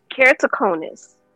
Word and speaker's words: Keratoconus: Keratoconus.